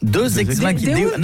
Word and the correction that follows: deus ex machina: Non, deus ex machina. – Ah, parce que deux, j'aurais dit que c'était deux ex que t'appelles machine. Tu vois. – Ça se prononce deus ex machina.